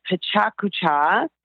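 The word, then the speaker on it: Pecha Kucha: I'm speaking at an event called Pachakucha. – Pachakucha. – And that's spelled Pachakucha, and it's spelled P-E-C-H-A, and then a space, and then the second word is K-U-C-H-A.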